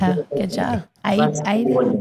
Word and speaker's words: Eid: Eid.